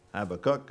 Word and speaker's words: Habakkuk: The Hebrew would have always emphasized the last syllable, so Habakkuk, I suppose, is how he might have pronounced it.